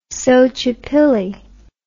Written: xochipilli

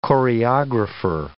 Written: choreographer

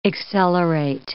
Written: accelerate

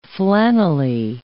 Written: flannelly

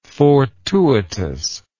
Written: fortuitousness

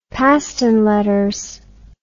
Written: Paston Letters

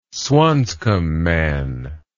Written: swanscombe man

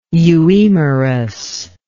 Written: euemerus

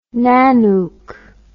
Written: Nanook

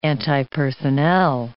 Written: antipersonnel

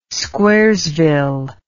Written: squaresville

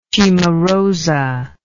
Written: cimarosa

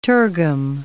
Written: tergum

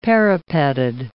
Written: parapeted

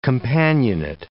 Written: companionate

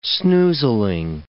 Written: snoozling

